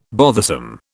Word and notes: bothersome: British English

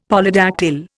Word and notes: Polydactyl: Indian

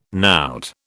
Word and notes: knout: British English

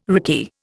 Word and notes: rickey: Indian

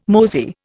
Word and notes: mosey: Indian

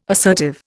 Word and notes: assertive: Indian

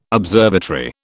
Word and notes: observatory: British English

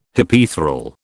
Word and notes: Hypaethral: British English

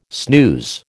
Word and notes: snooze: American English

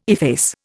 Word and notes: efface: Indian

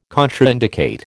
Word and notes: contraindicate: American English

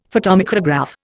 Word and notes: photomicrograph: Indian

